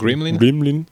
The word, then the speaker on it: GRMLN: c'est GRMLN, alors je sais pas comment ça se prononce, Gremlin,